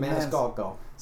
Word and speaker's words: Maniscalco: How do you say Sebastian's last name? – Maniscalco. – See, I can never get it right. It's a weird looking name to me, and it's Italian apparently.